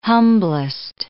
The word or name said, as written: humblest